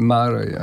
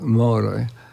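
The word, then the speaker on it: Márai: Ja, wie pani, mnie zaskoczyło ten mm, sławny obecnie węgierski pisarz Moraj, to znaczy, pisze się Maraj, a mhm. podobno się mówi Szandor Moraj.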